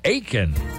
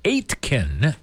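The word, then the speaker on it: Aitkin: The forecast brought to you by Riverwood Healthcare Center of Aiken. Some people would say Aitken, but it's the T is silent in Aiken.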